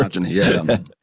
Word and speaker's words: hegemony: And he blamed it on cultural hegemony, or how do you pronounce that word? – Hegemony, yeah. – I get that one wrong. Hegemony.